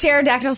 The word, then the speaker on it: pterodactyl: Because the pee is silent. – What? – Huh? – Oh, is pterodactyl start with a P? – Yeah.